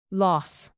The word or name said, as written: loss